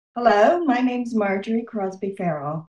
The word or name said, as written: Marjorie Crosby-Fairall